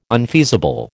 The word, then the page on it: unfeasible: American English